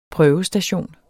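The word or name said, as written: prøvestation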